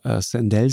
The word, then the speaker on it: Sandel: Sandel, spricht man den aus, ja.